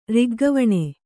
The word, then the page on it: ರಿಗ್ಗವಣೆ: riggavaṇe